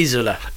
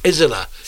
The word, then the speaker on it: Isola: Cafe Isola. – Isola. So like a Z sound. – It's Coffee Island in Italian.